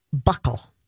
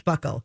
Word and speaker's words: buccal: It is pronounced buckle. – Is it? – It is. – Buccal. Okay. – Stick with me, kid. – Okay. – Okay. – Spelled like Buccal, pronounced Buccal.